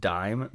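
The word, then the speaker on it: disme: Okay, it's still pronounced dime, but it has an S in between the I and the M for some reason. It looks like dismay, but it was pronounced dime.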